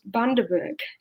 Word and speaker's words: Bundaberg: and we found out that it's pronounced Bundaberg.